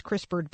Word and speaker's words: CRISPR: In the article, if you were to Google this, you could just Google CRISPR baby, and CRISPR is spelled like it sounds, except that there's no E. So CRISPR is C-R-I-S-P-R, CRISPR.